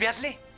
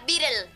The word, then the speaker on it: Beatle: ¿Y si viene el Beatle? – Se pronuncia Beatle.